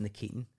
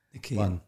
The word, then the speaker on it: Nikitin: So his name is pronounced Nikitin. – Nikitin. – You know, I always call him Nikitin because, you know, it's bad for your health.